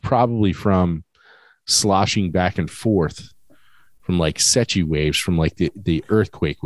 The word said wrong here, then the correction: seiche: That's, yeah, Seiche. – Seiche, sorry. I always want to pronounce it Secchi.